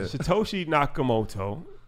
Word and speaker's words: Satoshi Nakamoto: Satoshi Nakamoto.